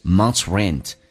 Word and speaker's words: month's: 第一句话， Amy can't pay this month's rent. 那么第一个我们讲听力的难点就是 month's rent. 因为 months, 像这个单词一般我们会读成 months, 这是标准的说法。但是在口语化当中，这个咬舌音再加个 s, 可以直接读成 t, 加个 s, 叫做 t, 叫 months, months, t 加 s, 叫 month's rent.